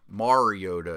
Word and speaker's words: Mariota: Mariota is right.